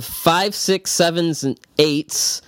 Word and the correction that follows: The 5, 6, 7, 8s: That is that's not how you say it. – See, five, six, seven, eight. The 5, 6, 7, 8s.